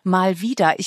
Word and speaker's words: Malwida: Ich habe das erst beim lauten Lesen so verstanden, dass da ja drinstecken könnte, mal wieder, man schreibt die Figur M-A-L-W-I-D-A, aber beim Aussprechen könnte es ja heißen, mal wieder, im Sinne von, mal wieder geht alles durcheinander, mal wieder können wir uns nicht verständigen.